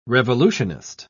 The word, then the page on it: revolutionist: British English